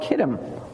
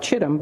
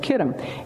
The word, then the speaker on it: Kittim: Now, Cyprus in the Old Testament was called Kittim, sometimes spelled like it looks like Chittim, but it's Kittim, is amazing.